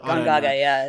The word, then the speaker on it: Gongaga: Another area that's ripe for sort of expansion for that sort of stuff is it Gongaga? Is that how they pronounce it? – Gongaga, yeah.